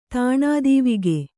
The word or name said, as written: ತಾಣಾದೀವಿಗೆ